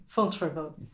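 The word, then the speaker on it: Fontevraud: But if there's one heart to it all in these later years, it's this place Which you'll pronounce for me. – Fontrevaux. – Perfect. You've done it much better than me.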